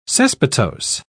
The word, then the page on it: cespitose: British English